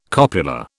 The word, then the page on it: copula: British English